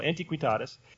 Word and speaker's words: antiquitatis: The news story is that a new specimen of Coelodonta antiquitatis, I missed a syllable, antiquitatis, was found.